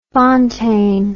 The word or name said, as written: Fontaine